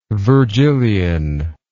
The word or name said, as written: virgilian